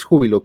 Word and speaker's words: Júbilo: Pero en el día a día sí es Júbilo, con el acento en la U.